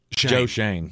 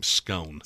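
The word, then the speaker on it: Schoen: Joe Shane. – Joe Shane. I wanted to call him Scone.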